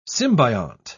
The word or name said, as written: symbiont